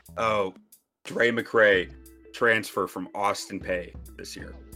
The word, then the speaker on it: Austin Peay: I mispronounced it Austin P, but I like the way I said it better.